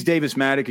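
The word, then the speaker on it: Davis Mattek: Okay, he's Davis Matic again.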